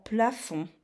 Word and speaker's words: plafond: Ou comme dans plafond aussi. Le plafond, il y a un dé muet.